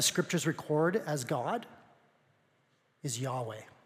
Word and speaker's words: Yahweh: That's how they believe the name of God is pronounced. Yahweh. Yahweh.